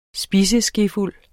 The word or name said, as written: spiseskefuld